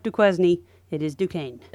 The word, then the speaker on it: Duquesne: When I were first discussing this, and I kept talking about Duquesne, and finally was like, it's Duquesne.